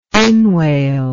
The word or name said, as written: inwale